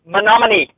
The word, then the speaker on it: Menominee: The pronunciation of the word in question is Menominee.